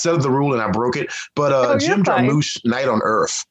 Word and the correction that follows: Jarmusch: Yeah, Jarmusch. Thank you. My mispronunciation.